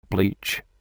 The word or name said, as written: bleach